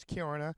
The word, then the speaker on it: Kiruna: and it's spelt like Karuna, but it's Kiruna.